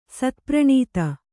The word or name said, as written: ಸತ್ಪ್ರಣೀತ